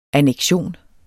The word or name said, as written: anneksion